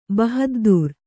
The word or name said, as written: ಬಹಾದ್ದೂರ್